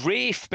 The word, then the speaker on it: Ralph: And the horse is trained by a trainer called, this is very British, you like, he's called Rafe Beckett. So it's spelled Ralph. Do you have this phenomenon in the US where people who were called Ralph are actually called Rafe? – Only in Rafe Fiennes, and he's an importer.